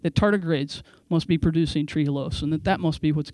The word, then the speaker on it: trehalose: Am I saying that right, Fuzz? – Yep. – Yeah, trehalose.